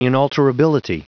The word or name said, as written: inalterability